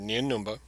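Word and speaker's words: Nien Nunb: By the way, I'm pronouncing that because that's how Jonathan Davis pronounced it in the Battlefront novel by Alexander Freed. I know everybody says Nien Numb, something like that.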